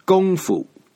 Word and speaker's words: kung fu: In the old Wei-Giles system of romanization, the word for kung was spelled K-U-N-G. Pinyin, G-O-N-G. Fu is still fu, no matter Wei-Giles or pinyin. So kung fu in Chinese is pronounced kung fu.